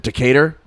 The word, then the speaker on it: Decatur: Big Bob Gibson's Barbecue in, what is it, Decatur? Yeah, that's how you pronounce it.